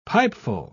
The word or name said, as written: pipeful